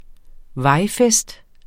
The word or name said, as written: vejfest